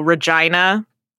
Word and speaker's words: Regina: Oh, Regina, because it sounds like vagina.